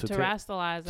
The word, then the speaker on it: terastallizing: And it was um terrorizing or to oh terastallizing. – Terastallizing. That's how you say it.